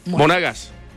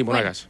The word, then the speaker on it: Monagas: Monagas. – Monagas. – O Monagas. – Sí, Monagas.